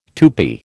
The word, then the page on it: Tupi: American English